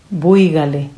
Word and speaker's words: buígale: Buígale. – Buígale. Tiene acento en la I.